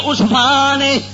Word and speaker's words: عثمان: این سین فے الف نون عثمان بار وزن عثمان یعنی جیمے لفظ عثمان پڑھا اسے طرح عثمان اسے نالے اے سین نالے تلفظ دا صحیح کرنا بھی کسی جگہ تے حوالہ پیش کرو تے غلطی مت کھا جاؤ.